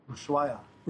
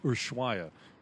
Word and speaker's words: Ushuaia: Ushuaia. – Ushuaia.